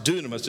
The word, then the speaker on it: dynamis: It is written D-Y-N-A-M-I-S, but it's pronounced dunamis.